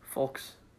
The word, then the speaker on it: folks: Don't imagine folks. The L. Just say F-O-K-E-S. Folks? Just spell it like that. Folks.